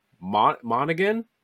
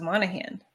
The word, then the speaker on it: Monaghan: And it was revealed they had the actor who's the voice actor for Cal Kestis from the video game, Cameron Monaghan. Is that how you pronounce that last name? – I always thought it was Monahan.